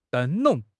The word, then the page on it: 灯笼: HSK6